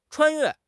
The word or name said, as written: 穿越